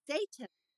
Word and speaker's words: Seyton: It's spelled S-E-Y-T-O-N. – And pronounced? – Satan.